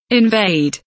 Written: invade

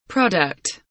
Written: product

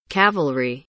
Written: cavalry

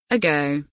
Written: ago

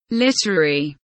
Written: literary

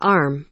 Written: arm

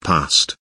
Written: past

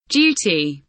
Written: duty

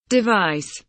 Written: device

